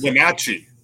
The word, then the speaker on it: Wenatchee: I can't pronounce Wenatchee.